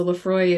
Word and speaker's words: Laphroaig: But at our Christmas party, I got to try Lefroy, which, by the way, the whole Celtic Gaelic language, just anything that is written, it is not pronounced the way that it is written. So Lefroy is Laphroaig.